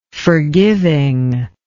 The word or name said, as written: forgivingness